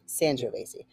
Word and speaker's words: Sangiovese: Am I saying that right? Sangiovese? – Sangiovese. – Okay, got it. Maybe it depends on where you're at, where you're from. – Yeah. – Because it's San versus San.